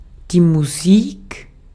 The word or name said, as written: music